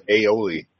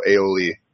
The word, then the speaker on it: aioli: Uh, it's spelled A-I-O-L-I. Um, and the word for those who might be interested actually comes from uh, the provincial ALH uh, garlic al, um, or in Latin that's allium uh, plus oil, OIL.